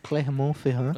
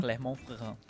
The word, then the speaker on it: Clermont-Ferrand: Quem confirmou o roubo foi a promotoria de Clermont-Ferrand. Ah, eu acho que falei certo agora, né? Clermont. – Assim, tem que fazer esse... né? Clermont-Ferrand. – Clermont-Ferrand.